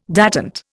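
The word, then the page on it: détente: Indian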